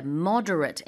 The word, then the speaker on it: moderate: The doctor recommended exercising at a moderate intensity 3 times a week. 자, 그래서 적당한일 때는 moderate 이렇게 발음하고요. 똑같은 스펠링이지만 moderate라고 하게 되면 동사고요.